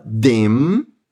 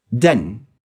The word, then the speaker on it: denn: Esse denn, que tem ele no meio da frase, ele é pronunciado bem rapidinho e é um som de E aberto, sem deixar o ar passar pelo nariz. Presta atenção, não se fala dem, dem, não, se fala den, den.